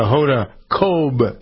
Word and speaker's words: Hoda Kotb: the Hoda Kotb thing is on.